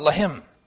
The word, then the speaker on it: Lehem: But Lehem is a word that, pronounced in the way I just said it, means bread.